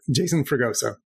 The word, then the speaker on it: Jason Fragoso: So yeah, it's Jason Fragoso.